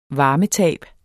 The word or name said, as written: varmetab